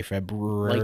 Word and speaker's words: February: There's an R in February. – There is an R in February. – No, there's not. – Yes, there is. – Nope. That's fake news. – It's a silent R.